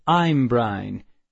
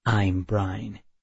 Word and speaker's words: Imbrin: After just a few hours, Miss Hornbill was like a new Imbrine." "Imbrine," she pronounced it. "It's Imbrin," I corrected her, unable to help myself. "The I sounds are flat."